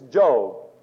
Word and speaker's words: Job: And it is spelled Job, but it's Job.